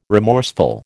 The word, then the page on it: remorseful: American English